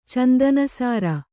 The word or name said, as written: ಚಂದನಸಾರ